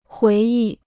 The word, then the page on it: 回忆: huí yì